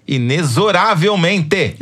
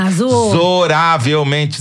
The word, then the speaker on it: inexoravelmente: Por favor, Ensinem ao Brasil que a pronúncia correta dessa palavra, por incrível que pareça, é inexoravelmente. – Ah, inexoravelmente. – Sério? – Idiota, eu. É, não é xô, é zo, segundo a Yara Ruda.